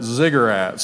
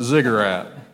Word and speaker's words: ziggurat: Uh, but it said ziggurat. So I thought of, well, not cigarette, but ziggurat.